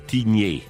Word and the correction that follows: Tignez: è Tignez, no? – Tignez. La Z alla fine, sì. – La Z in romancia, dove pronunciamo tutto ciò che è scritto come in italiano.